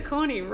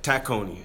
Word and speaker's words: Tacony: I say Tacony wrong. How do you say it again? Say it louder. – Tacony. – Tacony. – That's the way they say it here, so it's Tacony.